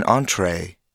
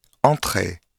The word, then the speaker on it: entrée: Listen carefully to the English pronunciation of the words café and entrée. Can you hear how the final vowel is drawn out into two different sounds? In French, however, each vowel is pronounced with a single pure sound.